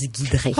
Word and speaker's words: Giedrée: Une soirée humour noir en chanson avec Pierrick et Guidré, on prononce Guidré.